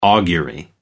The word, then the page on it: augury: American English